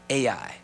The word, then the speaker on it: Ai: Now, you might not be able to see this, but this is called Haggai. I'm sorry. Ai. Hagar. That's what I'm thinking of. Ai. And you spell it Ai. It's H-A-I, but it's Ai.